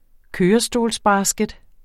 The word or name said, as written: kørestolsbasket